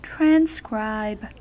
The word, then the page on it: transcribe: American English